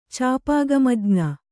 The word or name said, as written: ಚಾಪಾಗಮಜ್ಞ